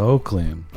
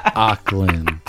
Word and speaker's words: Auckland: Just that he's Samoan and he was raised in West Auckland, what? – New Zealand. Not West Oakland, but West Auckland. – You know, West Auckland shit. That's hella funny. – It's spelled A-U-C-K.